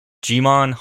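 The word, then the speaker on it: Djimon: Jimon Honsu, and Lee Pace all returning.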